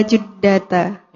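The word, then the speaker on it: juddata (جدة): Itu ju, ya, bacanya ju. Ila jud. – Ila juddata. – Ya, ila juddata. Jadi jeddah itu terkadang, bukan terkadang ya, para supir di sana menyebut dengan jeddah, jeddah, jeddah.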